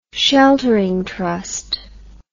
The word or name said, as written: sheltering trust